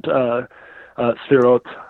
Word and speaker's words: Sephiroth: And the cross maps to such a degree that it like calls out the different Sefirot, or Sephiroth is how I hear most people pronounce it, like on the tree by virtue of the die cut that's basically containing the cover within a cover.